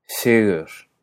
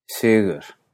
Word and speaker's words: Sigur: Así que Sigur se dice Sigur, Sigur, Sigur.